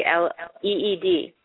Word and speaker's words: Alwaleed: Was that you know, it was in 2005 that Prince Alwaleed, and I say that incorrectly, I'm sure. You say it for me. Alwaleed. – Halloween sounds about right, yeah.